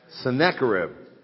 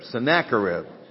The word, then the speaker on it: Sennacherib: I always get his name wrong. What's his name? Sennacherib. I always want to say Sennacherib. Sennacherib. There you go.